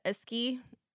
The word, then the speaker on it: ASCII: It's also been noted that he, whenever he doesn't want to speak, he will, and I forgot how to pronounce this and I just looked it up, the ASCII, you know, the ASCII, yeah.